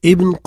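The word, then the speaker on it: ibn (ابن): Ibn ne prend pas de Hamza. Même si on a pris l'habitude de le prononcer Ibn, normalement, on doit enchaîner avec le mot qui précède. Normalement, on doit dire Hadha Tariq ibn Qasim.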